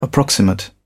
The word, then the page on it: approximate: British English